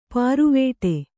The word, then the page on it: ಪಾರುವೇಟೆ: pāruvēṭe